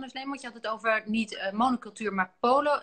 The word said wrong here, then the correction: polycultuur: Zeg ik het goed? – Polycultuur. polycultuur.